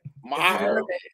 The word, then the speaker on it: Maher: Maher.